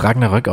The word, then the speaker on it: Ragnarök: Ja, ich glaube aber tatsächlich, dass es Ragnarök ausgesprochen wird. Also in allen Medien, die sich auch damit beschäftigen, und aber auch, ich habe Vikings geguckt, auch im Englischen sagen sie es so, sie sagen es im Deutschen so.